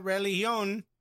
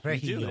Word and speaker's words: Reguilón: We didn't even talk about he got Reguilón. Reguilón? – Reguilón. – Reguilón, but I don't think you're supposed to pronounce the G. – It's a Rejillon. There's a G there. Oh, Reji? – Yes, no, Rejillon. – G-U-I is Gi.